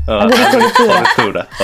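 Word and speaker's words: agricoltura: アグリコルトゥーラ。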